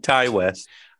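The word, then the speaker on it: Ti West: This movie was directed by, do you know, is it pronounced T or Ty? – Ty West. – I've heard it both ways, and I was always thinking Ty West.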